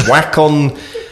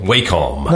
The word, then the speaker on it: Wacom: because he's recommended the Wacom... What's that? Cintiq? – It's Wacom, isn't it? Wacom. – No, Wacom. I always said Wacom.